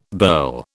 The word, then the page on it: burl: British English